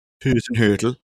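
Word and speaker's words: Hasenhüttl: I mean, I think he's a very underrated coach, how'd you say his name? Hassenhüttl. Is that right? – Hassenhüttl.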